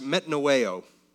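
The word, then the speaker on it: metanoeo (μετανοέω): Repentance in the Greek, which is what the New Testament primarily is written in, is pronounced metanoeo.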